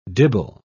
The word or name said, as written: dibble